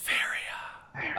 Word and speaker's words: Faeria: Faria. You have to say it like that.